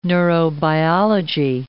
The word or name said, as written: neurobiology